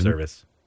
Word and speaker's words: Servais: but I do know that Seattle manager Scott Servais is... – Service. – Is it Service? – It's Service. – I've always said Servais. – I know. It's spelled Servais, but... – It's Service. – It's Scott Service.